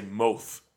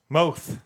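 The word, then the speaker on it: Mowth: And, yeah, the way they spell it, it's M-O-W-T-H. Almost like you want to say Moth. – Moth.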